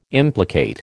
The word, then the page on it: implicate: American English